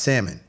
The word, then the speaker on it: salmon: It's just like salmon. – Mm-hmm. – It's actually pronounced salmon, even though there is an L.